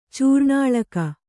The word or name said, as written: ಚೂರ್ಣಾಳಕ